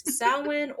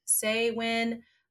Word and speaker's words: Samhain: But Samhain, but it is Samhain or Samhain. It just depends on like where you're from and how you have pronounced it.